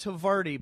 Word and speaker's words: Havarti: Yeah, and your little insert in every podcast you do, you mention Harskavarni cheese. – Which is actually pronounced Havarti, but you have some Havarni. Sort of black dot on your brain that prevents you from fucking saying it right.